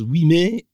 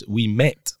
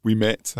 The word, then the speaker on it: Ouimet: Francis Ouimet? No. In fact, it's Francis Ouimet. – Ouimet, that's right.